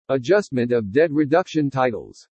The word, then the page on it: ADJUSTMENT OF DEBT REDUCTION TITLES: english